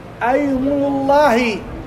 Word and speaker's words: أيمن الله: ومن الأمثلة الدالة على ذلك بين قوسين أيمن الله فتح الهمزة سكون الياء ضم الميم ونون. أيمنوا ضم النون وراها لفظ جار مضاف إليه.